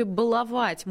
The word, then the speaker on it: баловать: Ударение в слове «баловать» может измениться, заявили в Институте Виноградова. Со временем словари все-таки назовут «баловать» основным вариантом, а «баловать» допустимой старшей нормой, ну, как только вот «баловать» исчезнет.